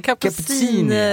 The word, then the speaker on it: Cappuccini: och nu ska jag ge mig på det, uttala det här så att det inte låter som kaffe då. Hur sa man det? – Jag vet inte. Cappuccini.